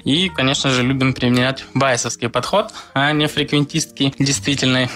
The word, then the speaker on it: байесовский: Артем меня сразу же поправил, сказал, что надо говорить байесовский с ударением на «а», не байесовский. Я всегда говорил с ударением на «е».